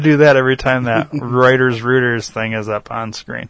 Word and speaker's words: Reuters: I'd definitely go Reuters. Reuters would be like R-O-O-T. – How do you get Roy out of R-E-U? – I'm just fucking with you.